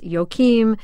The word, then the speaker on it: Joakim: Apparently his first name is pronounced all sorts of ways. I've pronounced it wrong. His Florida sports officials say it's Joakim. His coaches call him Joakim, Joakim, Joakim.